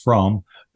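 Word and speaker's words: Fromme: And Squeaky is Squeaky Fromm, sometimes pronounced Fromm, who attempted to assassinate President Ford.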